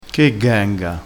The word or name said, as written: ghenga